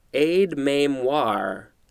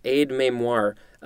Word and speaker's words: aide-mémoire: First word for today, I believe it is pronounced aide-mémoire. Yeah, aide-mémoire. A-I-D-E-M-E with an accent over it.